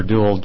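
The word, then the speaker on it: Deuel: I'm Peter Duell, District Attorney of Jefferson County.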